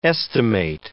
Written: estimate

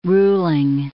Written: ruling

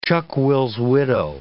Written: chuck-will's-widow

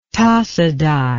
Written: tasaday

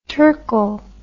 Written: terkel

